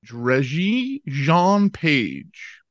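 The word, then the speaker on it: Regé-Jean Page: Is it Reggie Jean Page? – Reggie Jean Page.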